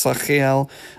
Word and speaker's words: Sachiel: And the other angel that is associated with Thursday is Sachiel, however you would pronounce it, friends. I'm pretty sure it's Sachiel, if we're thinking C-H as the sort of sound in Hebrew. S-A-C-H-I-E-L.